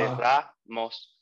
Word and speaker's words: Ramos: And Spanish is like super straightforward, where so the vowel sounds for A is just A. And so you would say Ramos.